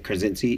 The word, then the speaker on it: Krasinski: So this is John Krasinski. I think I get his name wrong, right? – Mm-hmm. – Is it Krasinski? – Mm-hmm.